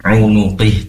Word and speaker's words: unuqih: Kemudian fi unuqih, nah itu bacanya unuqih, tadi tidak tidak tersimak jelas dalam membaca kalimat ini unuqih ya, maka diperjelas lagi, di situ ada ain nun yang berharokat dhamma, qaf kasroh unuqi, seperti itu bacanya.